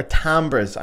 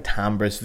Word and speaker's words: timbre: I saw a video recently where an American guy was explaining the word timbre, and he said timbre, pronounced timbre. Go on, mate, bruh. It's not bruh. Timbre is not pronounced like that, bruh. Timbre. It's a fucking French word, but timbre will do.